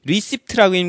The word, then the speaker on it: receipt: 이때 조심해야 될 것은 receipt라고 읽으면 안 돼요. 왜냐면 P가 묵음이에요. receipt, P 묵음이라고 쓰세요.